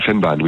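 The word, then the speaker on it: Vennbahn: Et alors, il y a une ancienne ligne, on parlait de la ligne du Van Bamme, c'est ça? – De la Fennbahn, oui, tout à fait. – Oui, pardon, ça prononce comme ça.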